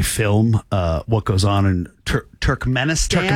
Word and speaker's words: Turkmenistan: Turkmenistan.